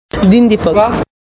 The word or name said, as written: Din din fuck